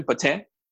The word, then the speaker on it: poutine: I know some people just put ordinary cheese and they call it poutine, but authentic poutine, as they pronounce it, correct pronunciation, poutine, is cheese curds.